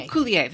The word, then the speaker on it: Coulier: Coulier.